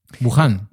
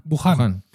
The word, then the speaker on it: Wuhan: Wuhan. Wuhan. – Estaban haciendo Pensé que estabas hablando de la isla de Wuhan.